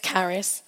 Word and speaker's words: Karis: I said, yes, hello, my name's Karis. No, it's not spelled C-A-R-Y-S. It's spelled K-A-R-I-S, bane of my life.